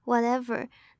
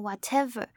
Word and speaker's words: whatever: 那接着我们来看到 whatever 这个字，whatever 这里的 t 因为夹在两个母音的中间，所以它也不会有很明显的送气，它不是念成 whatever。